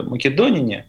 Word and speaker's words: македоняне: Ну, наверное, правильнее будет ударение все-таки поставить э, македонине.